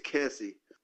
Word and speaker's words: Kesse: How did we decide to say this? Kesse or Kesse? – I think it's Kesse, but I said it wrong in the intro. – Okay, so we'll go with Kesse.